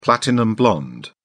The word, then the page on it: platinum-blonde: British English